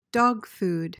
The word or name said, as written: dogfood